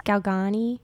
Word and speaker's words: Galgani: And I stumbled across Saint Gemma Galgini. I don't know if I'm saying her name right. Yeah. Saint Gemma. I think it's Galgani.